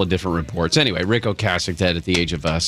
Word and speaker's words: Ocasek: I've found out that's how you say his name, Rick Ocasek.